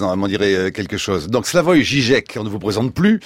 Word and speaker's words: Slavoj Žižek: Slavoj Žižek, bonjour. – Bonjour. – C'est comme ça qu'il faut dire, Slavoj Žižek.